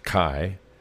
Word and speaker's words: chi: and it's not pronounced G, it's pronounced chi.